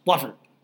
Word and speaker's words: Watford: No, that's Watford. – Oh, that's right. Watford, Watford, Watford, Watford. One syllable.